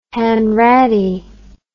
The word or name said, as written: Hanratty